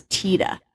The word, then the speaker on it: Tiede: This is the case of the Teda family murders. And I'm hoping I'm saying this right. It's T-I-E-D-E. And everything I could find says it's Teda. It's a German name.